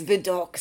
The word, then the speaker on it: Vidocq: Vidocq. – Yes, Vidocq.